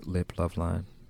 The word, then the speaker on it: Liploveline: So you spoke about back in 2020 when you started Liploveline.